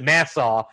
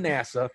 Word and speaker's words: NASA: But the admiral kind of pronounces it Nassau. And I thought there was a problem with my subtitles. I was like, Nassau, were they from the Bahamas? No, apparently they are from NASA.